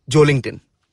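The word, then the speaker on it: Joelinton: You know, it's apparently pronounced how Wellington is, Joe Linton.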